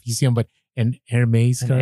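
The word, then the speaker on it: Hermès: Is it Hermes? – No, it's Hermes. – Hermes. – It's from France.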